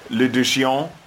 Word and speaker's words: l'addition: L'addition. – L'addition. – L'addition, s'il vous plaît. – Right. The L follows into, like, addition.